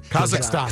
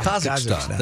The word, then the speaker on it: Kazakhstan: How do you say it? – Kazakhstan. – Kazakhstan. Thank you.